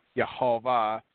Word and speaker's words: Yehovah: gave the correct pronunciation for the YHVH, which is Yehovah, which is anglicized as Jehovah.